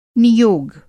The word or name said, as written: नियोग